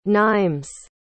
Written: Nîmes

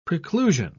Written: preclusion

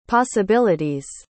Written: possibility's

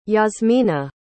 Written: jasmina

